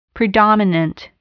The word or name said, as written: predominant